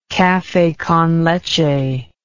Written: cafe con leche

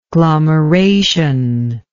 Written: glomeration

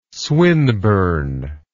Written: swinburne

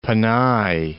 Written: Panay

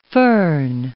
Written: foehn

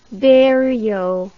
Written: berio

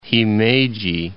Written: Himeji

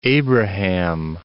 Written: Plains of Abraham

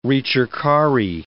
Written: ricercari

